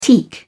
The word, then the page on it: teak: British English